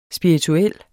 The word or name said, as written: SPIRITUELT